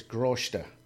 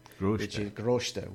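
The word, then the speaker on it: grásta: So it's Groshta. – Groshta, which is Irish for grace.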